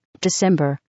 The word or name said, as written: december